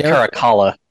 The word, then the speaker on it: Caracalla: Fred Hechinger played Emperor Caracilla. Well, I have more trouble pronouncing Roman names than Japanese names. How is that a thing? – I think it's Caracalla.